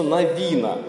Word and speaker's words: Навин: Правильно говорить книгу Иисуса Навина. Ударение на второй слог «Навин».